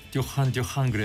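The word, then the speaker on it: Duran Duran: 파리에 갔더니 어느 고등학교를 취재 갔는데 파리 여고생들이 전부 듀란듀란을 듣는데 뭐라고 부르냐면 듀한 듀한 그래요. 그저 불어 발음으로.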